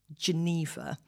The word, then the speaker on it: Genever: It's spelled G E N E V E R. So an English person might call it Geneva.